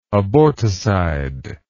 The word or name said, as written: aborticide